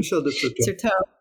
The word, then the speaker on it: Certeau: Certeau.